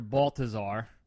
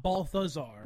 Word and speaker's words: Baltazar: But the photos I took were the storefront of Nick Cage's character Baltazar. – Baltazar, not Balthazar. – No, it is, Baltazar. – But that's how I call. That's how I pronounce it. – It was with a hard T.